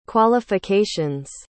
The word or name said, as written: qualifications